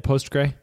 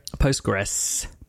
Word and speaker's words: Postgres: Is that how you say it, Postgre? Postgres. You have that S on the end.